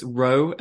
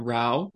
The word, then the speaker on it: Rowe: Just a little uh, side note: it's row and not row.